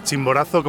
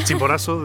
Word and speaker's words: Chimborazo: Tú vienes de Ecuador, de la región de Chimborazo, como pronunciaríamos en Madrid, sí, por ejemplo. Chimborazo, diréis vosotros.